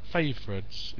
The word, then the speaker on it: favorites: And this word that's always causes trouble, favorites. It's two syllables, with the stress on the first. Favorites.